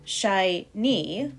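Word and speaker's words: shi-ni (四二): But also, in Japanese culture, the number 42 is considered unlucky, so maybe you should talk about it tomorrow, but anyway, because the numerals, when pronounced separately, shi-ni, 4-2, sounds like the word dying, like the Latin word mori.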